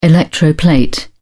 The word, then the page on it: electroplate: British English